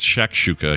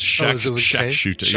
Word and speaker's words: shakshuka: no, it is a, so I'm spelling, I'm actually pronouncing it wrong. It's shakshuka. I keep saying shat with a T. It's shakshuka. Yeah, more like Shaq O'Neal, you know, like a basketball great, Shaquille O'Neal's shortcut name.